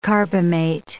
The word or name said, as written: carbamate